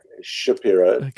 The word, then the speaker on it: Szapiro: Like Shapiro, still pronounced Shapiro, but spelled S-Z-A-P-I-R-O.